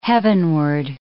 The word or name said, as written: heavenward